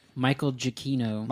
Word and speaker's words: Giacchino: Michael Giacchino.